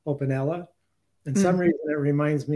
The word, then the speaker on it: OpenELA: And everyone's free to pronounce it however they want, but I pronounce it, I'm OpenELA. – Yeah. – Okay. – There's a lot of comments being come in. Ella, OpenELA.